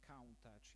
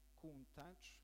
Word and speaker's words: Countach: Molti credono che sia una macchina con un nome inglese e che si debba pronunciare Countach, invece no, Countach è in dialetto piemontese,